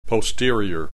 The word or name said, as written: posterior